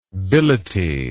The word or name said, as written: billety